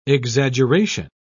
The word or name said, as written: exaggeration